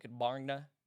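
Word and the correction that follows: Gbarnga: How do you pronounce your institution? – It's Gbanga, Gbanga.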